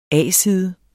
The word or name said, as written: A-side